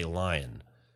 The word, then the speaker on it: lying: So the bad joke here, the punchline, depends on this cultural knowledge, at least in the West, that people will have a tendency not to pronounce the G in lying. So, you know, bad jokes work with a whole bunch of different mechanics. And that's one of my favorite mechanics, is that it uses pronunciation and the knowledge. It seats itself squarely in this knowledge that, you know, the majority of the people don't say lying. They say lion, which sounds just like lion, which is the punchline to the joke.